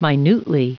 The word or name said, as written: minutely